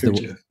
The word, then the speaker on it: Gurdjieff: And then I can never say his name. Girdeff was Gurdjieff.